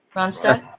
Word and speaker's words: Ronstadt: And am I saying his name right? Does anybody know for sure? The G is silent? – Ronstadt. – Ronstadt? Okay. – Yeah, correct.